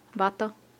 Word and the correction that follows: pato: P-A-T-O, pato.